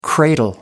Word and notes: cradle: American English

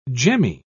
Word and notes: gemmy: British English

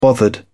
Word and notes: bothered: British English

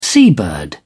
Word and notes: seabird: British English

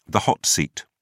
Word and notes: hot seat: British English

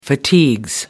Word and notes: fatigues: British English

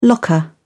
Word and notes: locker: British English

